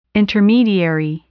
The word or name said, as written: intermediary